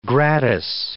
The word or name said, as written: gratis